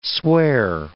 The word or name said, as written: swear